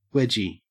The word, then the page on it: wedgie: Australian English